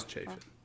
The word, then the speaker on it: Chafin: Well, first, I hate to be that guy, but it is pronounced Chafin. – Oh, does he say Chafin? – I'm it chafing is. – Right now. – It is chafing.